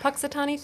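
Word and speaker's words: Punxsutawney: Puxatawny Phil.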